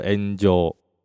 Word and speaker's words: 炎上: 哎，岩上它其实是从日本发展发展过来的一个网络用语啊，日文念作 Angel。 Angel 就是岩上的意思，那汉字中文就是我们写的那个岩上哈。